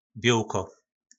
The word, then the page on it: bjolko: po nasimu